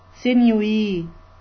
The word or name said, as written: sinewy